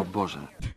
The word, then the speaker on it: Boże: And then the Romanian man, which I thought was really cool, tells you how to say it in Romanian. – I know. – Oh, my God. – Oh, Boże.